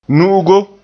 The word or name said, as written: nu'ugo